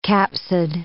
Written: capsid

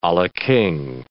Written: a la king

